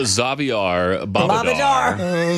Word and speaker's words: Xavier Bobadar: Is it Xavier Bobadar? – Xavier Bobadar. – Xavier. – You got to say it like you're from Western, Johnny.